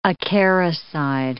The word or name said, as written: acaricide